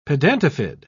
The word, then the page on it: pedatifid: British English